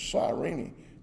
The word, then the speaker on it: Cyrene: Well, it's actually Simon of Cyrene. I looked it up. Sandy helped me pronounce it. It's Cyrene.